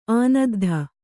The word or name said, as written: ಆನದ್ಧ